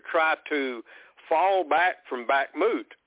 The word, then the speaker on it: Bakhmut: The Russians have encircled Bakhmut, I guess. Is that how you pronounce that city? – Yeah, Bakhmut.